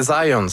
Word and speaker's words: Zajonc: Non, c'est Zajonc. – Oui? – OK. J'ai-tu pas dit Zajonc? – Vous avez dit plus Zajonc, mais... – Parce que j'ai prononcé un petit peu à l'anglais. J'ai prononcé mon polonais à l'anglais.